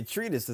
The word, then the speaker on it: treatise: Probably said that word wrong. The book a treatise is the proper pronunciation of that word. – I've never seen that before. – Yeah, treatise.